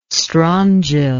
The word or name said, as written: strongyle